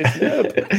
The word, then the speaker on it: herb: What, do you Americans not say the H in that word? – No, they just say herb. – Say herb. – We made up the thing called a silent. It's a silent H.